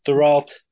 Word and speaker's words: Thoreau: Is that how you say it? – Yeah, that's close enough. Thoreau, that's how we say it in America. I think in French Canada they'd say it in with a French accent.